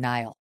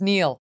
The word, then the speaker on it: Niall: But one thing about Niall is his name is spelled in a way that really makes you want to call him Niall. He's Scottish. Anyway, it's Niall.